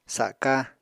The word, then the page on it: saka: apatani